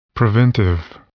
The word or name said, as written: preventive